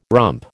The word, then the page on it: rump: American English